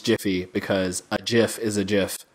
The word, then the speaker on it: GIF: It is GIF. – I say GIF. My sister always says, choosy tumblers, choose GIF. – The creator of the GIF said that it is pronounced GIF. So therefore, it is GIF.